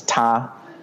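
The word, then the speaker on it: ta (他): The pronoun ta changes its pronunciation in almost every sentence, if you pay attention. So it loses the T sound, but it keeps the aspiration. It keeps the H. So the T becomes an H whenever it's the object of a verb.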